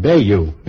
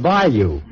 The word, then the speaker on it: bayou: They said it was a French word pronounced Bayou in Florida, and that they ought to know because they come from Georgia. Since then, I've looked it up in the dictionary, and I find that it's not a French word, but a Choctaw Indian word, Bayou, meaning a bayou, and pronounced Bayou.